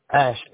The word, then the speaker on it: Ash: Ash, A-S-H. My first name is spelled M-A-R-C. – M-A-R-C.